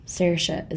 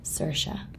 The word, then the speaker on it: Saoirse: Saoirse is how Irish people say it. – And how do Americans say it? – Saoirse. – Sounds kind of the same. – Sounds the same.